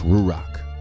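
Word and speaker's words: ruach (רוּחַ): Now, in Hebrew, spirit is pronounced ruach, right?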